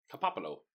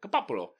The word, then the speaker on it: Coppola: Coppola. – Yeah, that's it. – Yeah, that's it. Coppola.